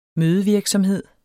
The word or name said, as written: mødevirksomhed